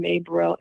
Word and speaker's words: Mae Bereal: It's just my name, May Burrell, M-A-E-B-E-R-E-A-L.